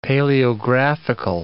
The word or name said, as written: paleographical